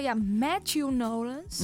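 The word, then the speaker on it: Matthew Knowles: Dus Matthew Nolens. Mm-hmm. zeg ik het goed? Ja.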